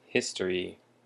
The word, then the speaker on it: history: And our next word that I'm sure everyone knows is history. History. And history has the emphasis on the first syllable.